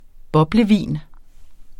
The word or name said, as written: boblevin